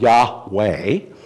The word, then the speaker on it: Yahweh: Secondly, Jehovah is a variation of the more properly pronounced Hebrew name for God, Yahweh.